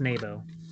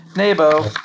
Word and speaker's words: Naboo: And let's not forget, it's pronounced Nabo. – Nabo.